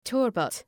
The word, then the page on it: turbot: ‘tɜ:rbət